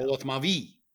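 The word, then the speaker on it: Polotmavý: They appreciate my pronunciation of Polotmavi. – I hope so. – Polotmavi, because there's an accent on the Y.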